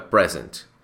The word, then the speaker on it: present: Que fica present. Se você fala, oh, I have a present for you, a pronúncia da palavra present está certo porque é um substantivo.